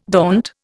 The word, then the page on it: daunt: Indian